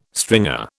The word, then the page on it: stringer: British English